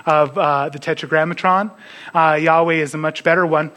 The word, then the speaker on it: Yahweh: We believe it's pronounced Yahweh. So if you read that, then Yahweh rained on Sodom and Gomorrah sulfur and fire from Yahweh out of heaven.